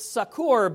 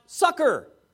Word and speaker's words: succor: I pronounce it succor, but in the Old English, it's actually pronounced sucker.